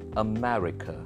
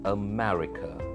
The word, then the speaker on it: America: America. America. So you give stress on meh. America. Russia. You have to stress on the first R-U-S.